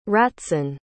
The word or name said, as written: ratzon